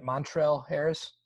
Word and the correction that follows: Montrezl Harrell: Montrezl Harrell. There we go.